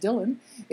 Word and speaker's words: Dillian: Also on Wednesday, we learned that Dylan Bailey, whose name is spelled Dillian, but it's pronounced Dylan,